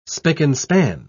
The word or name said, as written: spick-and-span